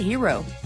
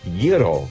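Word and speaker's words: gyro: Say hero. – It's actually pronounced Yiro. – Yiro. Oh. – Yeah, the G is silent.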